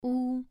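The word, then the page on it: wu: tone 2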